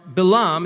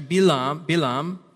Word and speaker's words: Balaam: Now, Balaam, it's pronounced actually Bilam, was one of the worst persons one could be, the opposite of Abraham in rabbinic literature and in biblical literature as well.